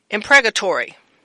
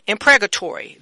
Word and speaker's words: imprecatory: It is spelled I-M. P-R-E-C-A-T-O-R-Y. Again, that's spelled I-M-P-R-E-C-A-T-O-R-Y. And that would be impregatory. Again, impregatory.